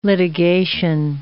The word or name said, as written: litigation